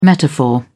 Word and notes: metaphor: British English

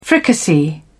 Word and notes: fricassee: British English

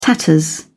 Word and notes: tatters: British English

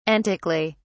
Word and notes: antically: British English

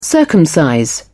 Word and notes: circumcise: British English

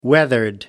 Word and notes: weathered: British English

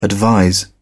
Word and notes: advise: British English

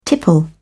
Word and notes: tipple: British English

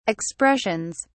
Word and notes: expressions: British English

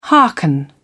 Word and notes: hearken: British English

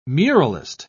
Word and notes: muralist: British English

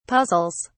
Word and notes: puzzles: British English

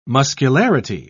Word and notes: muscularity: British English

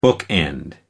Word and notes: bookend: American English

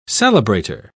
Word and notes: celebrator: British English

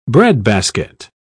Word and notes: breadbasket: British English

